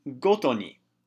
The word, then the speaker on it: 毎に: However, instead of being pronounced mai, it's pronounced goto ni.